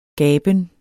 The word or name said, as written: gaben